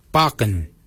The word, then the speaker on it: baginy: For beard, we say barken. Barken is spelled B-A-G-I-N-Y.